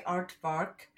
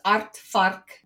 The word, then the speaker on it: aardvark: I always get the giggles when I hear North Americans say aardvark, because as South Africans, and I think any of our Dutch listeners will know, it's aardvark, but it's like die antwoord.